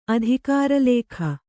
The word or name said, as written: ಅಧಿಕಾರಲೇಖ